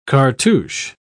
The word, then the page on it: cartouch: British English